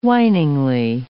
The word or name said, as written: whiningly